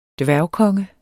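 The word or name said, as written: DVÆRGKONGE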